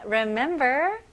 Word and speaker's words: remember: 네, 여기서는 스펠링은 re 이지만 발음은 ri 느낌으로 시작하면 좋아요. 그래서 remember 이렇게 힘 주실 필요 없고요. remember. Remember, remember. 네, 강세가 어디에 들어가죠? Remember. 네, 두 번째 딱 중간에 mem 부분에 넣으시면 됩니다.